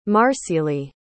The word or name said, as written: marcilee